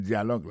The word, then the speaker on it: dialogue: Alors listen c'est écouter, again c'est encore, to part, tande yon parti in the dialogue, nan dialogue la. Alors mot dialogue ça c'est dialogue. Ça nous a remarqué là, nous a remarqué que l'accent tonique là tombé sous le premier syllabe là.